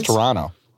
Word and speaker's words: Toronto: There's no T's in there. It's Toronto.